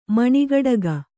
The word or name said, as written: ಮಣಿಗಡಗ